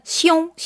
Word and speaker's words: 兄: 그래서 형 하면은 한자로는 시옹, 시옹 하고 발음하고 시옹.